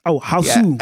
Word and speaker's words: Hausu: Can anyone pronounce it in the Japanese way? Is it House. – Boss. And... – Hausu. Yeah.